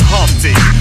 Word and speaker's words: Humpty: My name is Humpty pronounced with the umpty.